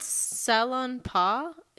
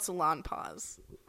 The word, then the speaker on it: Salonpas: It's called Salonpas. It's, like, spelled like a salon and then P-A-S. Yeah. – They're called Salonpas.